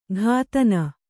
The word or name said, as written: ಘಾತನ